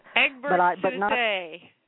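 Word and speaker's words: Egbert Sousé: But Egbert Soussay, accent on the E. That's what he says.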